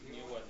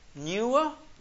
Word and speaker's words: Newark: Aquí es Newark, Delaware. – ¿Newark? – Newark.